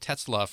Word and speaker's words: Tetzlaff: I'll spell that Tetzlaff for you. It's not a common name. T-E-T-Z-L-A-F-F, as my dad used to say, a good Irish name.